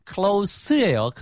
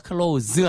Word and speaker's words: close: 그래서 감정적으로 가깝다라는 뜻으로 close예요. close 아니고 close. 가까운이고요. 똑같은 스펠링을 close라고 하시면 닿다라는 뜻입니다.